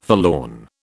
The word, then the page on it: forlorn: British English